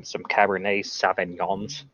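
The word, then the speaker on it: Cabernet Sauvignon: Some Cabernet Sauvignons. – Yeah. – Usually kind of like the heavier. Yeah, that's right. I said it right. – Yeah, you did.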